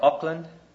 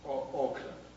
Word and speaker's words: Auckland: Or Auckland. I really can't pronounce that. How is that? Auckland? – Auckland. – Auckland, yes.